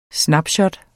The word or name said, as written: snapshot